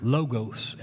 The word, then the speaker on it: Logos: Translated from the word logos, L-O-G-O-S. Logos is translated word. That's how it's pronounced.